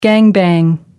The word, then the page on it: gang bang: American English